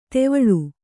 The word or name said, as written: ಧಾವಳಿ